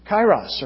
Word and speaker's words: kairos: And that word is kairos, or kairos is how most people usually mispronounce it.